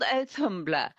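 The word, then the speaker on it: Audhumla: It starts with a cow called Alhambler, I think. Forgive me if the pronunciation's not right. – Yeah, she's called Alhambler.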